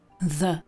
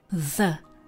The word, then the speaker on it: the: A tady to je člen určitý, který se před souhláskou vyslovuje jako the. The. Stejně jako brother, father, the. Se stejnou hláskou se můžete setkat i ve slovíčku this, toto, tohle, a nebo ve slovíčku they, oni. A výslovnost není ani české d, ani české z, ale takové něco mezi. Strkáme přitom při výslovnosti jazyk mezi zuby the, the. A tak trošku bzučíme.